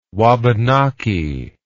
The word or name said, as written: wabanaki